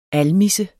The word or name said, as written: ALMISSES